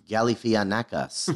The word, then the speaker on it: Galifianakis: It's Galifianakis is really how you pronounce it. – Galifianakis? – Yes. The media has got this wrong for years.